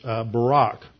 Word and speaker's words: Barak: In Hebrew, the accent's always on the second syllable, and so just like the prime minister former prime minister of Israel was Ehud Barak, that is how you pronounce the name here.